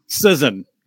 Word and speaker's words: Cizon: Cizon.